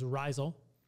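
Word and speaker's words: Rhizal: They say it's pronounced like mycorrhizal, like a fungus, so it's Rizal.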